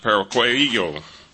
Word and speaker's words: pararrhueo: The Greek word for slip in verse 1 is spelled P-A-R-A-R-R-H-U-E-O, and is pronounced parakleio.